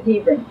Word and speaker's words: Hebron: I've always called it Hebron, and it seems funny to me that the Bible here has the pronunciations of Hebron, and yet when you listen to them on the news, on CNN, it's always Hebron. And so I'm wondering, have they changed the way it's supposed to be pronounced? But anyway, I've got a long E and an accent on the H-E. So I've always said Hebron.